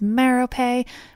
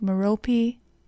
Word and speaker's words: Merope: Now, other versions of this story give the added details that the princess's name was Merope, which I'm pronouncing like in Harry Potter, but is probably more like Merope and that her father,